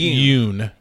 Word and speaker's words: Yoon: I know Jean it's Yoon. Yoon. I knew it was Y-O-O-N.